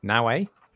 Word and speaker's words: Naoe: It follows the exploits of a shinobi by the name of Naoe,